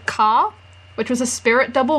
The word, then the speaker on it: Ka: Ka, K-A.